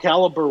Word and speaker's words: Calibre: That I believe it's pronounced Calibre. – Yeah, no, you're right.